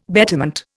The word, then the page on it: betterment: Indian